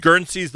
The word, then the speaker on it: Guernsey's: Is Guernsey's the right pronunciation? – Exactly.